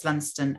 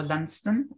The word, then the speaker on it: Lundsten: So if anyone wants to find me, you know, you can search my name, um, Violet's Lundsten, and Twitter. I'll probably come up. My username on Twitter, if you want to find me directly there, is V underscore Lundsten, and that's spelled L U N D S T E N.